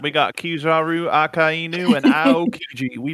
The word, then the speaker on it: Akainu: Akainu? – Akainu.